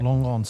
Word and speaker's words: Langon: Yourself, Langon. It's southeast of Bordeaux, Langon. Yeah. – Langon.